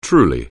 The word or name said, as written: truly